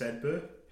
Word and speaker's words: Sedbergh: They faced the likes of, I never pronounce this one right, it's spelled Sedbergh. How do you actually pronounce Sedbergh? – I would say just Sedbergh.